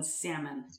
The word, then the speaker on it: salmon: You might as well just start pronouncing the L in salmon.